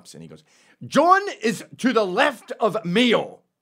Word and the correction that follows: Mao: Mayo? He was pronouncing Mao. Mayo. And I go, have you never heard the word Mao said aloud?